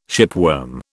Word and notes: shipworm: British English

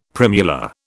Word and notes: primula: British English

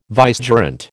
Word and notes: vicegerent: American English

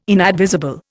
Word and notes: inadvisable: Indian

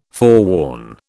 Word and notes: forewarn: British English

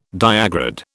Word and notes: diagrid: British English

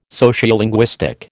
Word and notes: Sociolinguistic: American English

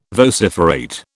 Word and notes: vociferate: British English

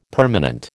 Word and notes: permanent: American English

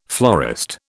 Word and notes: florist: British English

